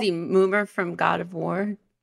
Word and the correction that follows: Mimir: Did I say it right? – No, you said it funnier. Oh. It's good. Mimir. – Mimir. Sorry.